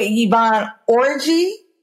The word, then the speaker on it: Yvonne Orji: Because we still ain't fucking with Molly, but we are fucking with Yvonne Orji. I think that's how you say her name. – Yes, ma'am.